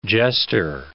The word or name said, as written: jester